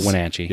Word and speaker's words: Wenatchee: That's not how you say it. It's Wenatchee. – Yes, please don't say it wrong.